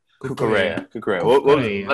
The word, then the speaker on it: Cucurella: Cucurella. – Cucurella.